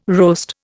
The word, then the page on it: roast: Indian